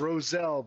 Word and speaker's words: Rowsell: Okay, it's Roselle, but everybody gets the S and the W swapped, so don't worry about it.